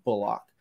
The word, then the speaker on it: Bullock: It's not Bullock. He said they've been mispronouncing his name for like 10 years. It's Reggie Bullock.